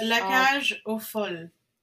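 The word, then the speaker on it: La Cage aux Folles: It's like Le Cage. – It's La Cage au Folle.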